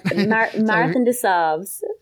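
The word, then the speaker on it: Marathon des Sables: So how do you pronounce Marathon de Sable? Is that right? – Marathon de Sables. Which is French for Marathon of the Sands.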